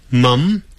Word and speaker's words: mum: 好， Mom，我们用英式英语写妈咪的时候，这个字会念成 mum，叫 M-U-M，两个发音，两个拼法都对。美式音呢是 M-O-M， Mom，小孩子呢会讲妈咪，就是 M-O-M-M-Y，妈咪。